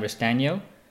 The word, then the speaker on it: Restagno: Today on the podcast, I have Sam Restagno.